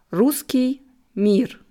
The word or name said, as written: Russki Mir